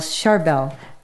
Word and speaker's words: Charbel: Saint Charbel, and that is spelled C-H-A-R-B-E-L, Charbel.